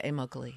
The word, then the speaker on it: Immokalee: Is that pronounced? – Yeah, Immokalee, yes.